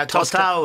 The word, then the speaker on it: Tostão: Tostão? – Yeah, Tostão was a great Brazilian player.